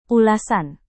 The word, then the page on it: ulasan: indonesian